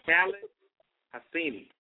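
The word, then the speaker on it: Khaled Hosseini: Khalid Hosseini.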